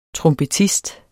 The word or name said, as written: trompetist